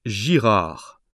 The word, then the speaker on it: Girard: Mon nom de famille est Girard. Ça s'écrit G-I-R-A-R-D.